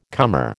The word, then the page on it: comer: American English